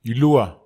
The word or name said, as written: ilua